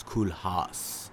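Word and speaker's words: Cool Haus: Yeah, but it's spelled HAUS. – Oh shit, house. – It's Cool House.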